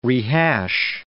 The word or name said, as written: rehash